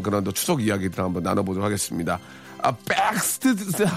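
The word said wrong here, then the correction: Backstreet: 아, 죄송합니다. 발음이. 아, 백을, 백을 너무 세게 했네요. 백스트리트 보이스의 노래입니다.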